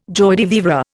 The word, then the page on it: joie de vivre: Indian